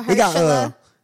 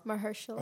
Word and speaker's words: Mahershala: Marshall Ali. Yeah, that's my name. Yeah, yeah. – Mahershala. – Huh? – Mahershala. – Oh, shit. That's how you pronounce his name.